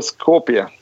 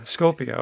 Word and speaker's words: Skopje: It's called Skopje. – Skopje.